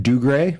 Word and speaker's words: Dougray: It does, but what we do get is when Dougray, am I saying his name correctly? – Sure.